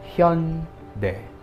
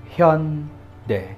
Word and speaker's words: Hyundai: The correct pronunciation of that is Hyundai. Hyundai. Hyundai. But because of romanization, many people thought it's Hyundai.